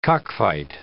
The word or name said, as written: cockfight